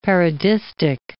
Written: parodistic

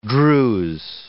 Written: Druze

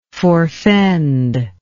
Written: forefend

